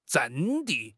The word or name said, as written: 阵地